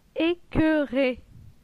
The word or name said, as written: Ecoeure